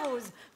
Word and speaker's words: Roz: Je m'appelle Nadia Rose. Voilà, c'est mon vrai pseudo. Ça s'écrit R-O-Z comme la couleur.